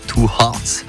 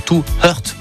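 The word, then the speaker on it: Two Hearts: Vous l'entendez, 2 hearts. Ça veut dire 2 cœurs. Oui, je sais. Mon anglais est impressionnant. Bien prononcé habituellement, je dis toujours two hurt.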